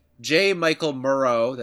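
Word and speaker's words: J. Michael Muro: go to J. Michael Murrow. That's how you say his name.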